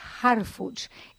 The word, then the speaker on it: Harfuch: Harfuch es H-A-R de Roberto, F de Francisco, U de Úrsula, C de Carlos, H de Héctor. Harfuch.